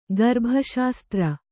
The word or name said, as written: ಗರ್ಭಶಾಸ್ತ್ರ